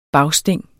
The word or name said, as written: bagsting